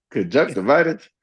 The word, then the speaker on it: conjunctivitis: I had freaking caught a case of conjunctivitis. Did I say that right? – Yeah, conjunctivitis.